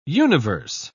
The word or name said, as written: universe